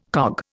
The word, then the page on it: cog: Indian